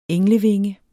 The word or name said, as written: englevinge